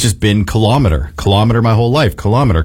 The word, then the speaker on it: kilometer: Second syllable stress, kilometer. The style is very common in everyday Canadian speech for some reason, influenced by measuring devices like thermometer and speedometer.